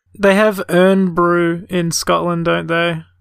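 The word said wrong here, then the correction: Irn-Bru: They pronounce it Iron Brew. – Okay, my bad. – It's like a bastardization spelling of the word iron. – Iron Brew.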